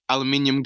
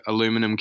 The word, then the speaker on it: aluminum: Y'all rock aluminium cans, or I think he pronounces it aluminum cans.